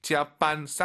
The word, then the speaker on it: 加班: 짜반 쌍비읍보다는 약간 비읍 느낌으로 힘 빼시고 짜반 하면 좋을 것 같아요.